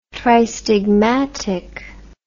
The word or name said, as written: tristigmatic